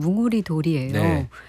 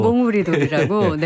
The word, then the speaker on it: 뭉우리돌: 제가 분명히 뭉이라고 쓰잖아요. 그럼 다 몽이라고 읽더라고요. – 몽우리돌이라고? 네. – 예, 몽우리돌하고 뭉우리돌하고 이게 두 개가 다른 돌인데, 몽돌은 이제 주먹만한 돌이고요.